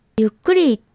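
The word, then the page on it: yukkuri: Japanese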